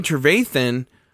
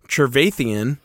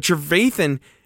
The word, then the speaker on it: Trevathan: It was a clean hit, but Danny Trevathan, or as the commentator who was on the sauce, I felt the whole game said Trevathian. If anyone was watching the game, they know he was mispronouncing Bears players' names left and right.